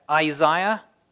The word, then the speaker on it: Isaiah: Let's look at Isaiah. That's the right way to say it.